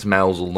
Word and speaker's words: Mousehole: We know it's Mousel, not Mousehole now.